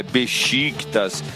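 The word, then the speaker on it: Beşiktaş: É que nem a pronúncia do Fenerbahçe. É, tá certo, é o Besiktas que fala, é Besiktas.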